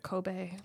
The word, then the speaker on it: Kobe: Do you say that Vissel Kobe? How do you say that? Anyway, Kobe.